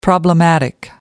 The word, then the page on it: problematic: American English